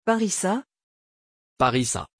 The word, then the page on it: parisa: French